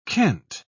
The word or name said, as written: kent